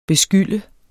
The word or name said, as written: beskylde